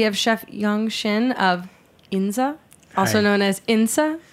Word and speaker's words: Insa: Tell me the actual pronunciation. – Inza.